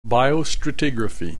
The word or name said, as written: biostratigraphy